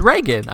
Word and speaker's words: Reagan: How to pronounce his last name? – Reagan. It's Reagan. I was right. Like Ray Gun, but without the U.